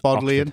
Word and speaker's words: Bodleian: That's in Bodleian Library, if that's how you say it. In Bodleian.